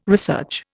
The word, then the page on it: research: Indian